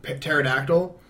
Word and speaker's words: pterodactyl: He, like, loads all these dinosaurs onto it and leaves Khazar behind, and Spider-Man webs onto a pterodactyl. And did you feel like that I pronounced the P by accident? – I was just picturing that.